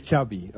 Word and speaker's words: Xavi: Xavi. – Okay. Yeah. So I heard it. Xavi like chocolate.